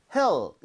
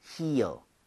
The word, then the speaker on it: heel: 그래서 얘는 그냥 hell 이렇게 하는 게 아니라 heel, heel.